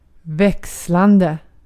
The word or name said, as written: växlande